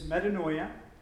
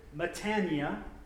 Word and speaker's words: metanoia: The Greek for that is metanoia, or one Greek Orthodox priest pronounced it metania, which made me wonder if he was from like Alabama or Georgia. I thought that was like a southern way to say it or something.